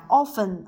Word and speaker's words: often: 哎，再有呢就是啊，我们讲 often 啊，或者读成 often 也可以，经常，哎，经常，然后总是啊，always，哎，always，哎，有时候我们也讲了 sometimes，哎，sometimes。